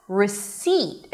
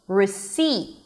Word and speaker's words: receipt: And the second syllable, which is the primary stress, receipt, is an S, a high E, and a held T at the end. C, receipt.